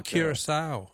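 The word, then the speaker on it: Curaçao: Oh, Curacao, yeah.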